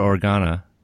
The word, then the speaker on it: Organa: Usually parents die unless you're Organa. Is that how you say it? – Yeah.